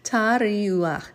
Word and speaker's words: teruah: I said tari. Earlier I said it wrong. It's tariu. I'm sorry. I'm looking at it right now as we read. I think I'm reading it wrong. Yep, I keep forgetting the ah.